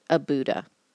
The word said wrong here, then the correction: Aduba: No, Aduba. Sorry. Sometimes I get B's and D's confused.